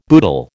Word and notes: boodle: American English

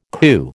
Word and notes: coo: American English

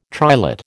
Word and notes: triolet: American English

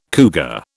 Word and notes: cougar: British English

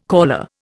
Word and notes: caller: Indian

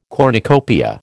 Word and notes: cornucopia: American English

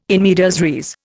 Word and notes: in medias res: Indian

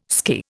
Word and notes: skirr: Indian

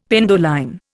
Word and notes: penduline: Indian

